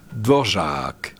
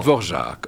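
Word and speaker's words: Dvořák: Y esa sinfonía es muy Dvorak, que lo voy a pronunciar como me han dicho los checos. – A ver. – Es Dvorak. – Oh. – Dvorak. O sea, la A es larga y la O es el acento.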